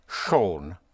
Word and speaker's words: schon: There's, there's a word which is used a lot compared to to to English, and that is the word shown. Shown, spelled S-C-H-O-N, which means already.